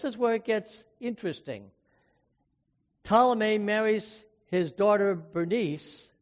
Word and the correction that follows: Berenice: or actually it's pronounced Berenice, to Sirius, King Antiochus II, Theos, after Antiochus divorced his wife Leotis.